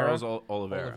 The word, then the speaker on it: Charles Oliveira: Charles Oliveira.